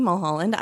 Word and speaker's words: Mulholland: I'm Nancy Mulholland.